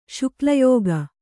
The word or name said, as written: ಶುಕ್ಲಯೋಗ